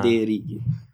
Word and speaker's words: Federighi: Federighi. Visto che è, simile italiano, il cognome lo pronuncio così, perché non sì, mi importa.